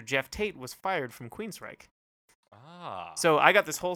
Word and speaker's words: Queensryche: Queensryche. – Queensryche. Telling me I've got to be aware. I think it's time to stop. – Queensryche.